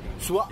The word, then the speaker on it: Su'A: Sua. – You gotta like Sua. – Exaggerate the A.